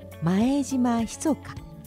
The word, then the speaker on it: 前島密: この郵便制度を作ったのが前島密か。密かっていうのはね、秘密の密と書いて密かと読むんですけれども、この前島密かさんで日本近代郵便の父と呼ばれ、その肖像が 1 円切手になっています。